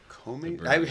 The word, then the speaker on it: Komes: But it's K-O-M-E-S is the. – Komes? Hey, how do you pronounce that? – Komes. Komes. Komes.